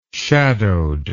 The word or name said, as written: shadowed